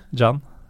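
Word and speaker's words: Can: Hvordan så du det? John. John? – Mm. – Ah. – Vanlig C på tyrkisk eh, uttales som engelsk J.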